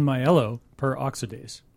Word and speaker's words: myeloperoxidase: Myeloperoxidase. – Per. I think the P-E-R is per, because it's saying there's one myeloperoxidase.